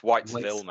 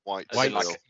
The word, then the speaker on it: Whitesville: So it might be helpful mentioning like how you spell that brand's name, Whitesall. So I'm not familiar with it either. – Maybe you should start talking about Whitesville. It's Whitesville, mate. – Whitesville.